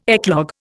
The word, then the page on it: eclogue: Indian